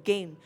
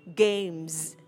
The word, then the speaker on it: game: Và ở đây mọi người để ý thêm một cái phần nhỏ về phát âm nữa, đó là không đọc là game trong âm E, mà mình sẽ đọc là âm E-I. Nó sẽ đọc là A, game, game. Ha, nó có âm M, âm M ở cuối chữ.